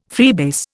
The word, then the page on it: freebase: Indian